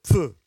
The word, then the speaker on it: Pfeu: Pfeu. En fait, ça se prononce comme ça, vraiment. Pfeu. Voilà. Ah oui, merde, ouais, puis surtout que j'ai enlevé la mousse.